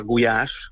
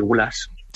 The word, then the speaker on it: gulyás: También se habla de la famosísima sopa gulyás, ¿no? Que en España se suele pronunciar gulas, Sí. – que no son las gulas del norte.